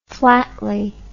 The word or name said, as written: flatly